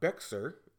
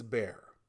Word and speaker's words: Bexar: Now, tourists to my hometown often mispronounce names, like it's not Bexar, it's Bear.